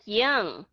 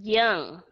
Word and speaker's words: young: Nó là young, nó là âm ơ.